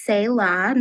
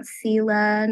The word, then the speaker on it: Selah: It is pronounced Sayla, not Sela, not Salah, you know, lots of other things.